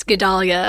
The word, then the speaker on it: Guedalia: Yes, Guedalia.